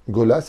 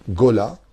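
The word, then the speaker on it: Gola (גולה): Gola, ça s'écrit Gimel, Vav, Lamed, He.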